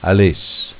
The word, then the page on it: Aless: Dialetto